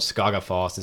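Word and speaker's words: Skógafoss: So like, I think it's called Skagafoss is how you say it.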